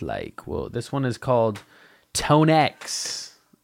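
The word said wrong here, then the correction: ToneX: Tone A, sorry. Literally says pronounced Tone A. So this is Tone A, not Tone X.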